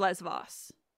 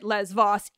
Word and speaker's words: Lesvos: And in modern Greek, it's Lesvos. But I know that in Greece, you pronounce it Lesvos, even if you're talking in ancient Greek.